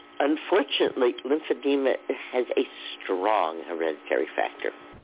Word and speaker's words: lymphedema: My father had very, very significant edema and swelling and lymphedema issues.